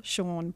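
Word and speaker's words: Siôn: His name is Sean, but it's written S-I-O-N, which is the Welsh spelling of Sean. So I just tripped up there because I'd written married couple Sean, brackets pronounced Sean.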